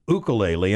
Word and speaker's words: ukulele: And when we interviewed, all my life I had called it a ukulele. It's actually pronounced ukulele.